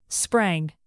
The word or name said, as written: sprang